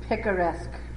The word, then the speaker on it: picaresque: The picaresque.